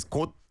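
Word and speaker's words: kot (꽃): And Siska says, "Kot." The pronunciation sounds like kot in my ear anyway.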